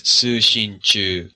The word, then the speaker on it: Sushinchu: I think the kana pronunciation is Sushinchu with equal weight on each of the syllables, which right. – makes sense since it's more or less equal weight in Chinese.